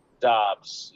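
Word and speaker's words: Doubs: Is it Dobbs? Romeo Dobbs? – Dobbs, yes, Dobbs.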